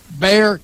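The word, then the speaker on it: Bexar: They're located in Spring Branch, Texas, and it draws kids from Boulevardy, Spring Branch, Canyon Lake, and North Bear County. I think that's how you pronounce it, Bear County. It's B-E-X there. I think it's Bear. I think that's how they call it.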